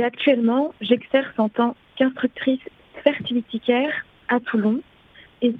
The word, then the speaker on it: fertility: Donc fertilitaire, F-E-R-T-I-L-I-T-Y.